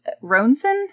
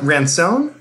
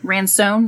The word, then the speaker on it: Ransone: Maya Taylor and James Ronson? Is that how you say his name? – I thought Ransone? – Ransone, that's probably more right.